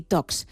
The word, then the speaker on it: Talks: Son charlas de tecnología, por eso Tech y Talks. Es que no se pronuncia la L, queremos ir de casi nativos, ¿no? Hay que pronunciarlo bien.